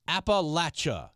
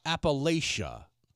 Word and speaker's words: Appalachia: Stephanie, it's pronounced Appalachia, not Appalachia.